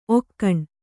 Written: ಓಖಣಿ

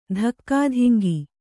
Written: ಧಕ್ಕಾಧಿಂಗಿ

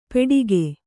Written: ಪೇಟಿಕೆ

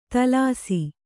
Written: ದೋಲಿಸು

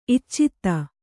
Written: ಇಚ್ಚಿತ್ತ

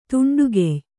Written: ತುಂಡುಗೆಯ್

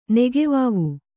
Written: ನೆಗೆವಾವು

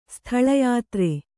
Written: ಸ್ಥಳಯಾತ್ರೆ

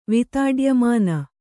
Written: ವಿತಾಡ್ಯಮಾನ

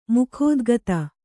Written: ಮುಖೋದ್ಗತ

